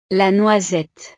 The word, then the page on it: la noisette: frances